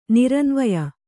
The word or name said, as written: ನಿರನ್ವಯ